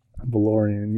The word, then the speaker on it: Valerian: Valerian. – Valerian, yes.